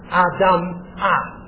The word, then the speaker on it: Adamah: Adamah. Except they would put the emphasis on the final syllable.